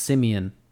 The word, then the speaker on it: Simeon: I thought his name was Simon, but it's pronounced Simeon. S-I-M-E-O-N.